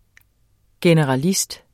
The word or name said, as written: GENERALIST